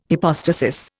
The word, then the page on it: hypostasis: Indian